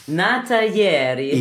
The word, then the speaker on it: Nata ieri: Nata ieri. – Ja, genau. – Habe ich das wenigstens schön italienisch ausgesprochen. Hast du super ausgesprochen, genau.